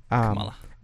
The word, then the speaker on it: Kamala: I watched Camilla's, however you say her name. – Kamala. – Chameleon. – Kamala.